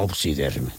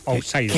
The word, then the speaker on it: outsider: ¿Outsider o Obsider? – Outsider. – Qué bien pronuncias el inglés.